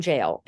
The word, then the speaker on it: gaol: They shot them in Kilmainham Jail. And if any of you go to Dublin and you get a chance to go to Kilmainham Jail, and jail is spelled G-A-O-L. – Okay. – The Kilmainham Jail, G-A-O-L.